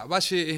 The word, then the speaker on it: Bashe: Mira, Valle es mi nombre artístico, eh, se escribe con, con, SH, lo cual es una deformación,